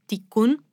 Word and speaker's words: Tiqqun: È appunto un concetto che è stato creato alla fine degli anni 90 da un collettivo di filosofi franco-italiano che si chiamava Ticun, direi che si pronunci così. – Come si scrive? – T-I-Q-Q-U-N.